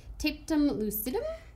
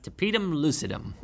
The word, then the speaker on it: tapetum lucidum: They have a membrane at the back of the eye called the tapetum lucidum. – It's pronounced tapetum lucidum.